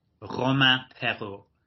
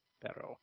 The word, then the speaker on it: Perraud: How do we pronounce his name properly? – Romain Perraud. – Oh, that's perfect. Okay, I'm just going to call him Perraud. – Yeah, that's good. Fine. As long as you don't pronounce the D at the end.